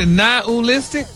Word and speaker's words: nihilistic: Nihilistic? – Wow. – Stop putting the ooh in there.